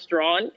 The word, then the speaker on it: Strachan: And then my last name, S-T-R-A-C-H-A-N. It looks like Strachan. It's actually pronounced Strawn.